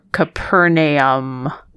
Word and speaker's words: Capernaum: It is generally sort of two syllables-ish. Capernaum. Capernaum. – Okay. – Yeah. An A or an E. So yeah, I've been eliding that and I shouldn't.